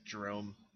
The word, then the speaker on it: Jerome: Like D-R-U-M? Like Drum? Drum? – No, or like Drone with an M. – Drome with an M. J-E-R-O-M-E.